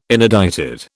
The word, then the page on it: inedited: British English